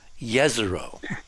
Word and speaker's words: Jezero: Yeah, except the J on Jezero is supposed to be pronounced, according to our sources, as a Y, Jezero.